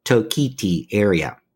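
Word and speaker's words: Toketee: That's T-O-K-E-T-E-E, Tokiti area.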